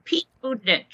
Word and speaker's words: Buttigieg: Okay, Pete Buttigieg. – Okay, Rick, can you say it? – Buttigieg? – Buttigieg, right? – Buttigieg.